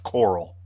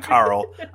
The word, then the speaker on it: Carl: And yes, everybody, that's how his name is pronounced. It's pronounced Coral. It's not Carl. I've heard Rick say it a million times. It's Coral, like the great coral reef.